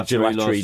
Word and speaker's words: gelatory: Oh, and the rum and raisin that the gelatory did was amazing. – Yeah. Yes. Rory would like us to call it the gelatory. – Gelatory. – Oh, okay, rhymes with Rory.